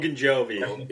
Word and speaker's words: Ogunjobi: Ogunjobi, you know how to say his name? – Ogunjobi, yeah.